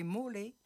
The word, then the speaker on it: mole: Le nom du monument Mool en Tonnelliana s'explique très simplement par ses origines géographiques et historiques. Pourquoi Mool ? En italien, le mot Mool, prononcé mole, désigne un monument de proportions monumentales, colossales et imposantes.